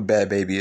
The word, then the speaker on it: Bhad Bhabie: It says, Bad Baby, and for y'all who don't know who Bad Baby is, you can always look her up. It's spelled B-H-A-D-B-H-A-B-I-E.